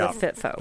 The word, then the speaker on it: FITFO: Everybody needs to understand that the emphasis goes on the F with FITFO.